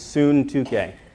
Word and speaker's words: Syntyche: Euodia, and the Greek word is pronounced Syntyche. It's strange. I've always pronounced it wrong my whole life. I think it's Syntyche.